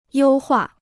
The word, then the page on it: 优化: yōu huà